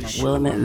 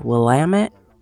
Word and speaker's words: Willamette: So yesterday, I was out in the Willamette Valley. Wait, am I saying that wrong still? Willamette? Willamette.